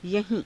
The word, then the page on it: yahii~: Hindi